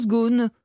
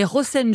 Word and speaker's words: Ghosn: Déjà, juste un détail, au Liban, on ne dit pas Carlos Ghosn, mais Rosen, je crois. – Oui, c'est ça, hein. On dit même Rosen, puisque le GH se prononce ici R.